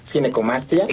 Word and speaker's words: Ginecomastia: Pues muchas gracias, y sí, definitivamente creo que es una, para empezar la palabra, le decía, ¿cómo se pronuncia? Ginecomastia, ginecomastia. – Sí, exacto. Ginecomastia.